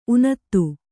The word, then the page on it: ಊನತೆ: ūnate